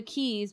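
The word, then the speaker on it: Quays: And we went to the Keys, but it's spelled with a Q.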